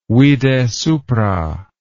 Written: vide supra

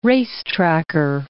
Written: racetracker

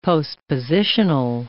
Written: postpositional

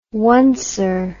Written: oneser

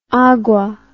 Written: agua

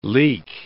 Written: leak

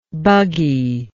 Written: bugginess

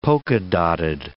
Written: polka-dotted

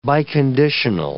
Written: biconditional

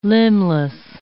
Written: limbless

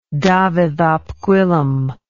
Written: dafydd ap gwilym

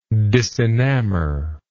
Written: disenamor

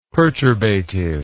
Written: perturbative

